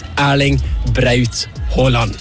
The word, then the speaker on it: Erling Braut Haaland: It's Erling Braut Haaland.